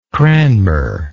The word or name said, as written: cranmer